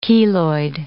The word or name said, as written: keloid